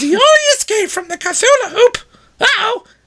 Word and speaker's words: Cthulhu: I figured there's going to be plenty of ads for the Cthulhu Hoop.